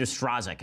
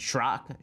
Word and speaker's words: Strzok: So, you recall, a lot of them come down to this one guy, Peter Stroke, Peter Strzok. It's spelled S-T-R-Z-O-K. I have no idea how to pronounce this, so I'll just pronounce it Schrock, Schrick. I'll just pronounce it differently every time, make fun of his name.